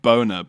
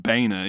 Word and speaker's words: Boehner: It's basically Mike Boner, but he claims it's pronounced Boehner, even though it's spelled with an O. And an E.